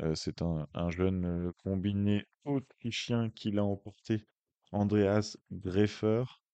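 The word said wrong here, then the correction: Gfrerer: euh, Ferrer, pardon, Ferrer, GFR, hein, je vous défie de, de le prononcer, R.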